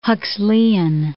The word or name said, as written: Huxleian